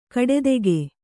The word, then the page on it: ಕಡೆದೆಗೆ: kaḍedege